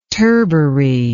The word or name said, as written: turbary